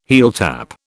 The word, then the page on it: heeltap: British English